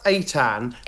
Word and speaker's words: Eitan: So it's pronounced Eitan. As soon as I started like circulating early drafts of the book, people were saying, how do you say the name? And so at some points quite early on in the book, someone gets his name wrong and he says, rhymes with spray tan.